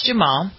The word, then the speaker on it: Duhamel: Oh, it was the Josh Duhamel. What's that? Is that how you pronounce his name? – Oh yeah, Josh Duhamel.